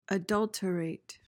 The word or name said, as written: adulterate